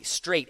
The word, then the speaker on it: strait: And it's spelled S-T-R-A-I-T, all right? So that's not S-T-R-A-I-G-H-T. S-T-R-A-I-T, straight, as in straitjacket.